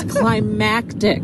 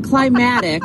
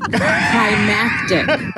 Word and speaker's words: climactic: It's climactic. Not climatic, climactic.